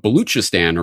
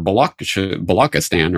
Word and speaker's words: Baluchistan: Um, for example, several years ago, I was writing about Baluchistan or Balakistan, or it's spelled and pronounced different ways in English.